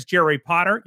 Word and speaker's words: Jerry Potter: My name is Jerry Potter. Yes, it rhymes with the boy wizard.